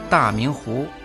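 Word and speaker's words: 大明湖: Это известное во всей стране озеро Таминху Та означает большой, произносится в четвертом тоне. Мин означает чистый, ясный, произносится во втором тоне.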